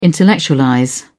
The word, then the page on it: intellectualize: British English